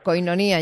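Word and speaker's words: Koinonía: ¿Koinonia lo digo bien o lo estoy acentuando mal? – Koinonia. – Ya me parecía a mí que faltaba aquí un acentito.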